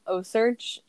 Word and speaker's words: OCEARCH: Oh, and if you are really into sharks, O-Search, if you haven't heard of it, it's spelled O-C-E-A-R-C-H.